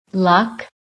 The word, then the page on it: LUCK: American English